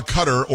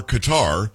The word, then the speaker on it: Qatar: He's in Doha, Qatar, or Qatar, depending on how you like to pronounce it.